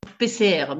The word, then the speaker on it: PCR: Ça se dit donc PCR, Mystère PCR. Ça s'écrit en toutes lettres, mais c'est PCR, voilà.